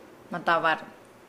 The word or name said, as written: madavalu